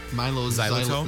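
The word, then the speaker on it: Mylo Xyloto: And I also like the first two tracks, Milo Xyloto and Hurts Like Heaven. – Yeah, that's great. You're pronouncing it right, by the way. I just looked it up. Milo Xyloto. Xyloto.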